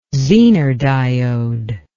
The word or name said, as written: zener diode